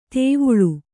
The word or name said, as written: ದವಳ